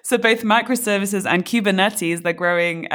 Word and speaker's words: Kubernetes: And so the adoption of cloud native technologies like microservices and Kubernetes, how do I pronounce this? Kubernetes? – Nobody knows how to say it. You know, nobody knows how to say it. It's Kubernetes. It's a Greek word.